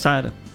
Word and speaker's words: Sahara: É isso aí mesmo, Sahara. – Não tem o H, não se pronuncia.